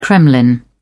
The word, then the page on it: kremlin: British English